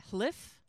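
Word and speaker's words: Hlíf: How do you heck do you pronounce that? Uh, hlif.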